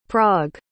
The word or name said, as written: prague